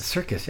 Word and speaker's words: Serkis: Serkis, yeah.